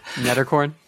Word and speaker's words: Nederkoorn: Nederkoorn.